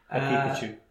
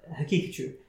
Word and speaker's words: Haqiqatjou: On the flip side, you have other people, uh, good brothers like uh, uh, Brother Daniel, even though I can't pronounce his second name properly. – Haqiqatjou. – Haqiqatjou. Okay,